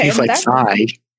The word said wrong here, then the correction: Sia: Or Sia, or however you say her name. – Yeah, like Sia, yeah.